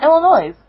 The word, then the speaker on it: Illinois: Is it Illinois? – It's Illinois. The S is silent.